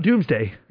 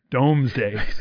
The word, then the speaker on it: Domesday: Domesday. – This is relating to the Domesday book or Doomsday book, right? I mean, like, got it. – Might actually be pronounced Doomsday, but it looks like Domesday.